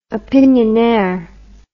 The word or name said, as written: opinionnaire